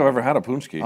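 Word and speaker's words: pączki: Is that like It looks like pakski, but the true Polish heads know it's punchki, all right? – All right, punchki.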